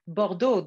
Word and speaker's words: Bordeaux: Bordeaux.